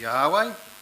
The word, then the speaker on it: Yahweh: And at the top of the slide there you can see the Hebrew characters, and they are transliterated into the Roman characters YHWH, generally regarded as being pronounced as Yahweh.